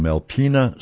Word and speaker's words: Alpena: You're from Alpena, South Dakota.